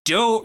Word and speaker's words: Do: We're here to talk about Do Retry. Just a heads up, I know it is written as D-O, but per Tucker, it is pronounced Do, like how Homer Simpson says.